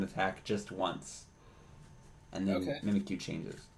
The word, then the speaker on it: Mimikyu: It mimics you. Mimikyu.